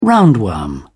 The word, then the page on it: roundworm: British English